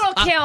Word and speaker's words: Lil' Kim: Lil' Kim. Yeah, see? Oh, I miss Lil' Kim. – I'm sorry, I said that wrong. Lil' Kim. – There you have it. – Because who needs consonants? – I love it.